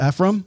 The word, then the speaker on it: Ephraim: It's E-P-H-R-A-I-M. – Ephraim. – Is it Ephraim? – Mm-hmm.